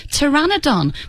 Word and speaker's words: Pteranodon: Pteranodon, which is surprisingly difficult, because it's got a silent P at the beginning of it. Pterodactyl, another silent P.